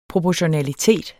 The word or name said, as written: proportionalitet